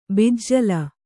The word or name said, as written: ಬೆಜ್ಜಿಲು